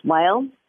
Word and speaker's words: Weil: What they did was they partnered with an acclaimed wellness pioneer, Dr. Andrew, I think his name is pronounced Weil.